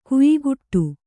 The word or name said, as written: ಖಯಖೋಡಿ